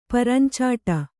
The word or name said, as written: ಪರಂಚಾಟ